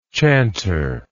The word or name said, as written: chantership